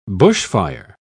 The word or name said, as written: bushfire